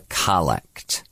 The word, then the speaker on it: collect: And there's the invitation to what we call the collect. It's spelled just like collect, C-O-L-L-E-C-T. – Collect. – But it's pronounced the collect, which you might think, you could think of as the opening prayer.